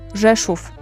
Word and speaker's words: Rzeszów: Rzeszów. – Rzeszów.